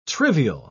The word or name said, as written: trivial